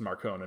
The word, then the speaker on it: Marcone: I recently found out that it's supposed to be pronounced Marcone and not Marconi.